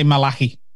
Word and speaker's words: Malachy: it's spelled M-A-L-A-C-H-Y, so I'm going to say Malachi.